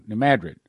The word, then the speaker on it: New Madrid: I keep saying it wrong. New Madrid.